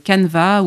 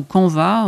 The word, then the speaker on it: Canva: et qui animera un atelier sur le logiciel Canva ou Canva, on peut prononcer des deux manières,